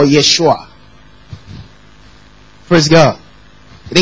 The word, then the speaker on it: Yeshua: Jeshua, right? Or Yeshua. But you replace the J with the Y.